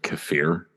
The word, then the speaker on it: kefir: Kefir. – K-A-F-I-R? – Yeah, yes. Kefir often pronounced kefir.